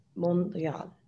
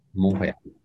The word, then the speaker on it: Montréal: but the last letter is typically not pronounced in French. – Montréal. – Montréal.